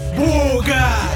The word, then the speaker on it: Буга: Буга. Ударение на О.